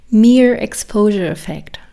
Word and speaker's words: Mere Exposure Effect: Ähm, aber dieser mehr, mehr Moment okay, jetzt habe ich es einmal gegoogelt, ich kam ein bisschen dumm vor, es spricht sich aus, Mere Exposure Effect.